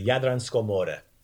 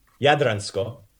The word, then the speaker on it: Jadransko more: The sea that we have in Croatia is the Jadransko more. – Jadransko more. – Right. So the accent's on the first syllable.